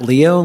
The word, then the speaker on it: Leow: Members of the committee, for the record, my name is Matt Leo. Last name is spelled L-E-O-W, and I'm here representing the Montana chapter of Backcountry Hunters and Anglers, regretfully standing in opposition to Senate Bill 442.